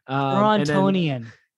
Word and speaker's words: Torontonian: And then want, of course, some of your thoughts as the Toronto fan here, the Torontoite, Toronton? – Torontonian. – I was going to say Torontonian. Wow, Torontonian. That's weird because you said you don't like to pronounce the second T in Toronto.